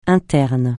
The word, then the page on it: interne: francais